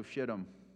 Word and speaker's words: Shittim: An acacia grove, also Shittim yeah, I said that right. That's how you pronounce it.